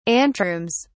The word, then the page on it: anterooms: British English